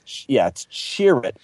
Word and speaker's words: Chirrut: I would say it's Chirrut. The spelling C-H-I hyphen capital R-U-T came from me asking Lucasfilm to clarify it because – everybody kind of pronounced it differently.